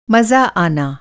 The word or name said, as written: मज़ा